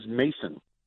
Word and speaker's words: Mason: My last name is Mason. M-A-S-O-N. – How do you mispronounce that? – Everybody that I talk to says I say Manson.